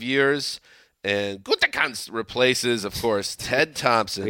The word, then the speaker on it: Gutekunst: That's a good pronunciation. – Gutekunst.